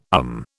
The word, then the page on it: um: British English